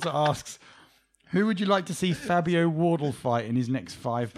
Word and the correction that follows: Wardley: It's got a Y on the end. – Oh, sure. – There we go. Wardle. – There you go. – Wardley.